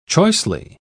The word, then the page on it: choicely: British English